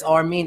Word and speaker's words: Armin: But it's Armin. It's spelled A-R-M-I-N, my real name. – Okay, so it's not Armen. – It's not Armen at all.